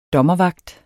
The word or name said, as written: dommervagt